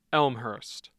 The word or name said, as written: Elmhurst